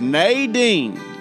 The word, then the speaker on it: Nadine: I was asking Clay and asking Brandy the other day, how do you say her name? They said, as Southern as you can think. I said, listen, it's not Nadine. It's Nadine. Y'all pray for Miss Nadine.